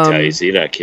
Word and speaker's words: Thalia Zedek: I didn't realize that Thalia Zed, Zedek, is that how you say it? Thalia Zedek? For, of yeah, Thalia Zedek, yeah.